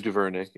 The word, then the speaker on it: DuVernay: but I know people like your favorite Ava DuVernay, or whatever, how you pronounce her name. – Yeah. Know, Ava DuVernay.